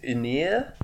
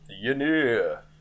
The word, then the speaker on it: Ynyr: His name is Ynir in the film, I think is how it's said. It's spelt Y-N-Y-R, which is awful.